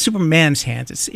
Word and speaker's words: Superman's Hands: That was a real catchy, poppy track, just great, called Superman Hands. It's Superman's hands. It's possessive. I didn't say it right.